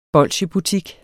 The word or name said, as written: bolsjebutik